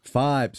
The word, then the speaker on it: Fybe: Am I saying this right? Fiber? – Fibe. – Sorry. – So I believe. Yeah. Yes. F-Y-B-E. – F-Y-B-E. Fibe. Yeah, you tell me how to pronounce that.